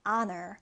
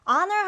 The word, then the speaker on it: honor: 자, 이 단어는 여러분 H 발음이 나지 않아요. 묵음 처리되어서 honor, honor입니다. honor 하면 명예, 영예를 얘기해요.